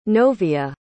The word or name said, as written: novia